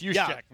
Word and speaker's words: Juszczyk: You're close. Juszczyk.